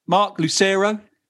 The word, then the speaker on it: Marc Lucero: Welcome back, Mark Lucero.